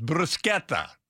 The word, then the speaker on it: bruschetta: So with this word, the most common mistake I've seen is to pronounce these like bruschetta. – Yeah, wrong. – That never you're doing it shetta if you do it like that. It's bruschetta.